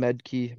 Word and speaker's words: Maedke: Medke. My last name is spelled MAEDKE.